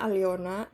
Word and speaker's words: Alena: tulisannya Alena tapi bacanya Aliona Aliona mm-hmm.